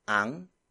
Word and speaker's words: ang: The first focus marker is ang. Ang. Ang. Ang is spelled as A-N-G.